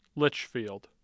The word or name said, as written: Litchfield